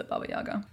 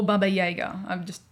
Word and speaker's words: Baba Yaga: What's your brief or limited understanding or of the Baba Yaga? Or Baba Yaga, whichever pronunciation you prefer.